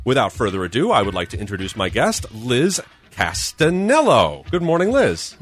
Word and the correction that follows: Castellano: Castellano. Castellano. – C-A-S-T-E-L-L-A-N-O?